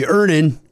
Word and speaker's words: Earnin: Earnin. – Earnin. – E-A-R-N-I-N is pronounced Earnin.